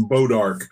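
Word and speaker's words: Bois d'Arc: It's Bodark. – Bodark? Boys de Arc. Yeah, it's pronounced Bodark, but it's spelled Boys de Arc.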